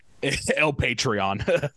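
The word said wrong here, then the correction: El Patrón: I think I butchered that. – I believe it's El Patron.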